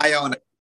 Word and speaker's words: Iona: Because you know Iona.